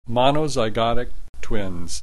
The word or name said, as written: monozygotic twins